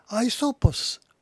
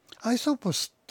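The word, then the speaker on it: ア イ ソ ポ ス (Αἴσωπος): ですから古代ギリシャのイソップさんこれ正しく言うと、えー、当時の古代ギリシャ語で発音するとアイソポスアイソポスというふうに呼んでいるらしいです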